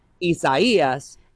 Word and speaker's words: Isaias: just as it sounds out because it's in Spanish, Isaias is going to be one that we're going to have to say a lot over this next week.